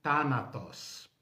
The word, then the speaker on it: Thanatos: Thanatos. Esse TH é mais ou menos como o TH do inglês, né?